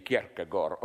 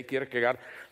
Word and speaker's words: Kierkegaard: I read in a biography of, how to pronounce it correctly, Kierkegaard. Okay, we in the corrupted West say Kierkegaard.